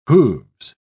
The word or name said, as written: hooves